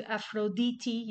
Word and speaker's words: Aphrodite: In Greco-Roman times, Venus, the planet, not the goddess, which is actually correlated to Aphrodite. Yes, that's how you say it, or at least some Greeks say it.